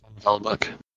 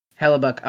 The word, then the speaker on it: Hellebuyck: Hellebuyck? – Hellebuyck. – Hellebuyck.